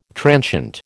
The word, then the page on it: transient: American English